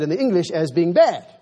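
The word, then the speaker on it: bad (בד): In Hebrew, it's spelled B A D, bad.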